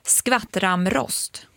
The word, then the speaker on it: skvattramrost: Jag var tvungen också att söka på det här namnet för bara, hur uttalar man det här? Jag tror att man säger skvattramrost. – Skvattramrost. Precis. Ja, nu ska vi komma ihåg det här, för nu har vi lärt oss något nytt.